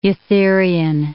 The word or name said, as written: eutherian